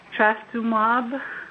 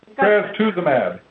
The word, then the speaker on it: trastuzumab: Okay, moving back onto our usual schedule here, our stated schedule, we have measure number 1857, Trastuzumab. – I'm sorry, how do you pronounce that? – Trastuzumab.